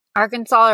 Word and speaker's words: Arkansas: So when you can actually get fined for pronouncing the last S in Arkansas. So if you say Arkansas's, you can get a fine.